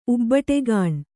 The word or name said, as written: ಉಬ್ಬಟೆಗಾಣ್